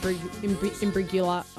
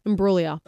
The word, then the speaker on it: Imbruglia: Imbruglia.